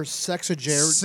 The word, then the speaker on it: sexagenarian: It's a game show host, icon, former sexagenarian. – Sajak. Jesus. – Wait. What's the last word? – Sexagenarian. – Sexagenarian? – No, that is how you pronounce it.